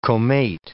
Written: comate

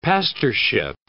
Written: pastorship

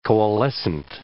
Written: coalescent

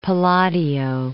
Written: Palladio

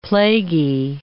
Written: plaguy

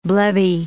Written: blebby

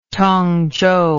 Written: tongzhou

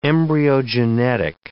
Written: embryogenetic

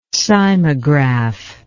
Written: cymographic

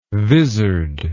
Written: visard

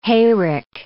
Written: hayrick

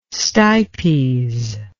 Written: stipes

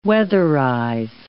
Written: weatherize

